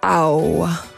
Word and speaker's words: Ao: Ao, c'est belge aussi, et Ao ça se prononce comme le cri du chat en anglais mais sans le M, et du coup ça fait Ao.